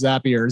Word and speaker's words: Zapier: The CEO taught me a mnemonic about how to pronounce Zapier, Zapier. And I, oh yeah, they did. Happier makes you happier.